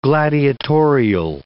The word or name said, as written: gladiatorial